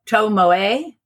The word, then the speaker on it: Tomoe: One of them, the oldest of the two, I'm going to say her name is spelled T-O-M-O-E. So I'm going to say Tomoe.